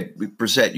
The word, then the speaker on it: Brissett: But if they didn't have any confidence in Brissett, is that how you say his name? – Yeah, Brissett, you got it.